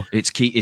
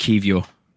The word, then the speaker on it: Kiwior: And we also got the Polish centre-back, Kiora, whose name I say wrong, who played every game for Portugal. No, the other people. Kivior. It's pronounced Kivior.